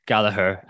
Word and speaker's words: Gallagher: Douglas Luiz, Bowen, Gallagher, and Ronaldo. Someone on YouTube actually told me it's a hard G, remonstrating that it's a hard G, and he'd apparently asked the man himself.